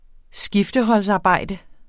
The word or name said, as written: skifteholdsarbejde